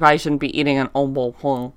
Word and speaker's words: Au Bon Pain: Au bon pain.